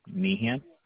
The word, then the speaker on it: Meehan: David Meehan, and that's spelled with an MEEHAN.